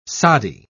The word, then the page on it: soddy: British English